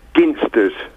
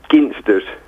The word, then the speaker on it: Ginsters: Well, being a Cornishman, it's Ginsters. – Hard G. – Yep. – High five for the hard G. – Yep. – I thought so. The girls here who don't know how to do... it's a J. You say it like a J.